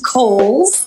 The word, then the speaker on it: Cowles: It is pronounced Cowles. George Cowles was an early land owner developer out in the East County area. So Cowles Mountain, like the department store, we want to respect the dead, right?